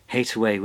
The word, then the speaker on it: Hathaway: And Hataway was an alternative pronunciation of Hathaway, the maiden name of Shakespeare's wife.